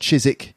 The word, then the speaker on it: Chiswick: Chiswick, it should be pronounced Chiswick.